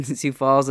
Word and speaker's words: Sioux Falls: You know, we were like trying to figure out, we didn't realize that the OUX in Sioux Falls is silent, you know, or the X is silent.